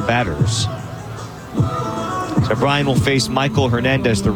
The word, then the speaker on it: Maikol: Michael Hernandez, who spells his name M-A-I-K-O-L, but pronounced just like Michael.